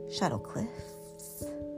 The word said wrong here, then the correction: Shadow Cliffs: some other siblings called it shadow clips, and they swore to us that that's what it was. I found out later I was saying it wrong to everybody. It is indeed, in fact, shadow cliffs.